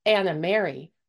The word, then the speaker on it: Anna Mary: The Horseback Librarians tells the tale of the fictitious Anna Mary, rhymes with library, as she travels her rounds delivering books to kids in their homes and schools.